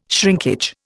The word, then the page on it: shrinkage: Indian